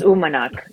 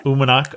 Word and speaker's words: Uummannaq: Well, the other question for you, and I was seeing that in some ways, the island that you're on now, which is Uummannaq or something like that? – Yes, Uummannaq. Yep. – Uummannaq. Okay, I wasn't sure whether you pronounced the two U's separately or not.